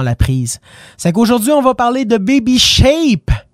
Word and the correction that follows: Baby Shark: Baby Shake, Baby Shark, on va le prononcer correctement.